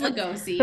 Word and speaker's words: Lugosi: It, I think it's Lugosi. – Performance. – Mm-hmm. That's how they pronounce it in season 3, at least for.